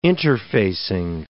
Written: interfacing

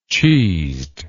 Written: cheesed

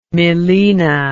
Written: melaena

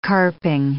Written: carping

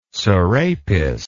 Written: sarapis

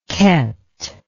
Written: ket

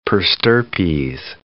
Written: per stirpes

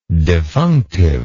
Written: defunctive

